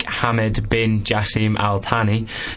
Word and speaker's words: Hamad bin Jassim Al Thani: Qatari Foreign Minister Sheikh Hamad bin Jassim Al Thani said Syria faced sanctions if it did not cooperate.